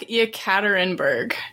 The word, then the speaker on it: Yekaterinburg: Was about 20 kilometers outside of the city they were in, of Yekaterinburg. That was a very butchered sentence completely. I apologize. – I think I tried to Google how to pronounce it and it came back Yekaterinburg.